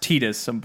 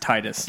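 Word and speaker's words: Tidus: It's pronounced Tetus, but I'm like... Fool, the English language dictates that is pronounced Titus.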